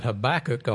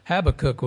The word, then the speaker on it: Habakkuk: If you're from West Texas like me, I've pronounced that Habakkuk all these years, and I've done that wrong. I got Google to tell me how to actually pronounce that prophet's name. So Habakkuk was the prophet, and Habakkuk received a pretty strong word from the Lord, a promise from him that wasn't very encouraging.